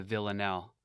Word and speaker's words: villanelle: This is some old school self-help gold delivered with humility and poetic brilliance via the villanelle. Because French likes them silent letters and vowels, that's spelled V-I-L-L-A-N-E-L-L-E if you want to search it up.